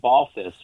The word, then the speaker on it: Balthvs: I would say there's a band from Colombia named Balthus, which is spelled B-A-L-T-H-V-S.